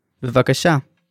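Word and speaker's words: bevakasha (בבקשה): In Hebrew, please is bevakasha. Bevakasha. Let's break it down by syllable. Bevaka. Sha.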